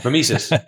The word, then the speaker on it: mimesis: Before getting into some of your key lessons, in the introduction, you speak a lot about mimesis, if I'm saying that right. – Mimesis. – Mimesis. Thank you.